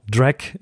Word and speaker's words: Dreck: Drek, we have a German word, D-R-E-C-K, and we pronounce it Drek, which is used by, it literally means dirt.